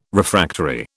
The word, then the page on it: Refractory: British English